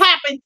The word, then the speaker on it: Papin: That is the story and my horrible pronunciation of the Pepin sisters. – Okay, well. – The Pappin girls. – The Pappin sisters.